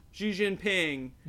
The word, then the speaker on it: Xi Jinping: either Trump president or to keep Xi Jinping. Am I pronouncing it right? – Yeah, I'm pretty sure that's correct. – Xi Jinping at the top dog in the spot.